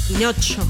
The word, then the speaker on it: Pinocho: Pinocho. – Grave, pinocho es grave. Tiene el acento en la anteúltima sílaba, como todas las palabras grave.